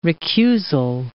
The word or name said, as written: recusal